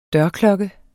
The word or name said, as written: dørklokke